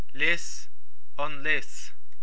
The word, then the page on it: Lios na Graí: Irish